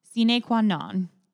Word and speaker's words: Sine Qua Non: Sine Qua Non.